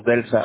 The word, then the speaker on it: Delsa: Okay, I'm going to spell it for you. DELSA, right? – Yeah.